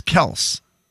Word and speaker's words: Kelce: Travis Kelce had a TV show called Catching Kelce that was like a Bachelor show, and on that show they called him Travis Kelce the entire season. – But he said the actual pronunciation of our name is Kels.